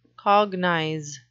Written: cognize

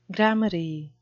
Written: gramarye

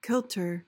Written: kilter

